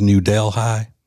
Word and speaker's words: New Delhi: They finally caught this thief over in the way they pronounce it is New Delhi.